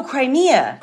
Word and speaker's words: Crimea: Not Croatia, but Crema, C-R-E-M-I-A. Crema. – Oh, Crimea. – Crimea. Yes. I knew I was getting it wrong.